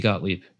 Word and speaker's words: Gottlieb: Lindsay Gottlieb. I'm bad at pronouncing last names. – Yep, Lindsay Gottlieb.